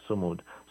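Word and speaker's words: Sumud: And for those who are listening to us, Samud is spelled S-U-M-U-D. S-U-M-U-D, Samud.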